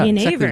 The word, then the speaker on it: Genever: Yeah, Genever, exactly.